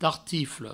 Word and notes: tartifle: provençal

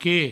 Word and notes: que: provençal

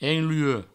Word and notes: enluec: provençal

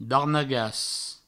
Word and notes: darnagas: Provençal

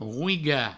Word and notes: roigar: rouiga; Provençal

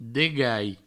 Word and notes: degalh: Provençal; degai

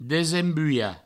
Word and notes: desembulhar: Provençal; desembuia

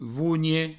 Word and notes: vonhe: vougne

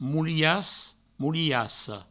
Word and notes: molias: moliassa; moulias; mouliasso

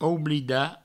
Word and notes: oublidar: oublida

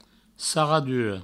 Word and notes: sarradura: sarraduro

